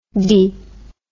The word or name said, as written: de